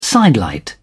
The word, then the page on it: sidelight: British English